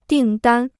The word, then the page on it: 订单: dìng dān